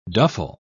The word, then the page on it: duffle: British English